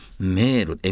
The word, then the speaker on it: mer: それからフランス語では海はメール MER ラメールダダダダダダダダメール、それで母はメールって言って同じ発音で MERE だ。